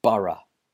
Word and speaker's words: borough: And be careful with the pronunciation of this word. The spelling is B-O-R-O-U-G-H. Borough. It's a strange pronunciation because it's got that O-U-G-H. Sounds a bit strange, but it is pronounced borough in British English. In American English, it's pronounced borough. So note the difference there.